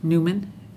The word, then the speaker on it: Newman: This is Linda Newman, and my last name is spelled N-E-W-M-A-N.